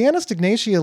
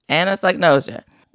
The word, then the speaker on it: anosognosia: The anosognosia label that I pronounced wrong, how do you pronounce that word? – Anosognosia.